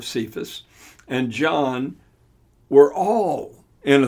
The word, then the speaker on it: Cephas: And when James, Cephas, or Cephas, as it's sometimes pronounced,